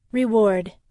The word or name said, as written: reward